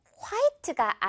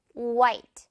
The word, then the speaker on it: white: 우리가 표기를 그렇게 하기 때문인데 화이트가 아니라 지금 여기 H 친구는 날아가죠. 유령됩니다. 그래서 white 이렇게 발음이 돼요. white. H는 그냥 여러분 철자는 있지만 아예 존재하지 않는다고 생각을 하면 돼요. 그러면 W 다음에 바로 I 발음 white 이렇게 나오는 거죠.